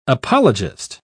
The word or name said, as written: apologist